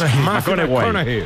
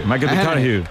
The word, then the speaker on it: McConaughey: ¿Matthew McConaughey? – Sí, sí, sí, sí, sí. – Que hay que ir corrigiendo la pronunciación. – McConaughey. – McConaughey.